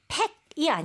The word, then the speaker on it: pack: 이거는 pack이 아니고요. – 네. – A 발음이 늘어지는 소리로 pack. – 오케이. Okay, so P-E-C-K가 아니네요. – 네. – Right? P-A-C-K. P-E-C-K는 뜻이 다르잖아요.